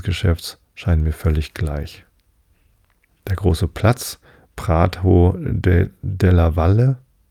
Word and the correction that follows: Prato della Valle: hätte ich beinahe spanisch ausgesprochen, della Valle, nee, es ist italienisch, Prato della Valle, hoffe ich, genannt, ist ein sehr weiter Raum, wo der Hauptmarkt im Juni gehalten wird.